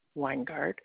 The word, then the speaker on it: Weingart: It's either under Linda or Ray Weingart, and my address is 3749 Plantation Boulevard, Leesburg, Florida, 34748.